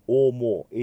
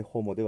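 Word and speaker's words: homo: オモ、 H を発音しませんのでホモではなくオモになります。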